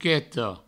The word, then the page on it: mochetas: moucheto